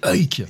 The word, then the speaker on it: Huyck: Bah, c'est quoi, c'est Huick Comment il faut Willard Huyck. Ah c'est Huyck.